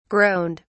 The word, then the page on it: groaned: British English